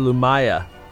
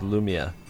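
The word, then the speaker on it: Lumiya: I keep wanting to call her Lumaya, but I know the correct pronunciation is Lumia.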